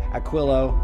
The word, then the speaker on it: Aquilo: You just heard the track Human by Aquilo. I probably said it wrong again. – No, you smashed it. That was great.